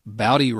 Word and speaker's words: Bowdy: Bowdy rhymes with howdy.